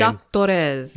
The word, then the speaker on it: Jacques Torres: Jacques Torres.